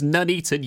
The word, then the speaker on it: Nuneaton: It's Nuneaton, you donut.